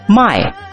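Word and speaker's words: 卖: So pay attention to the pronunciation. Buy. 买。The third tone, 买。 – My. – Sell. My. – The fourth tone.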